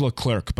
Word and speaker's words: Leclerc: It's Leclerc, by the way.